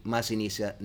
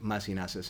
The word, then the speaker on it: Masinissa: It is really Masinisa, not Masinasa, even though I think Masinasa sounds better.